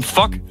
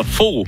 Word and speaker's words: Fogh: Til mit bryllup udtalte præsten mit navn som fuck. – Nej. – På trods af, at jeg hedder Fogh. – Nej. – På samme, altså, hvor man udtaler på samme måde som statsminister Anders Fogh.